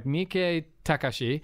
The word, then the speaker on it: Miike Takashi: So it's like Miike Takashi is how you pronounce it,